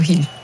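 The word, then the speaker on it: Gil: C'est ce gardien Orlando Hill, je l'ai bien prononcé, on dit Hill ou pas? – Si, Orlando Hill. Oui.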